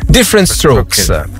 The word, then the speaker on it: Different Strokes: Titolo originale era Different Strokers. – Different Strokes.